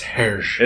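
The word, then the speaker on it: Hergé: Yes, old comic by Ergé. – I believe it's Hersh.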